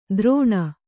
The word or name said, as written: ದ್ರೋಣ